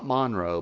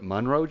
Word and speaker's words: Monroe: Not Monroe, but Monroe, Georgia is how you say it if you are from or lived in Monroe.